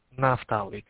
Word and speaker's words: Naftali: How you say Naftali? – I pronounced that Naftali, correct, yes.